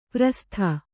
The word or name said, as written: ಪ್ರಸ್ಥ